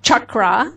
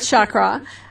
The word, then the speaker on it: chakra: Okay, the word is chakra. – Okay. – It's not chakra. And I, by the way, I apologize for correcting you because everybody mispronounces it, including you. – Yeah. – It just seems to be the popular pronunciation, which is not, that word doesn't exist. Chakra does not exist. There's no such word in the English language or in the Sanskrit language.